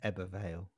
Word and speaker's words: Ebbw Vale: Is it Ebbw Vale? – Ebbw Vale.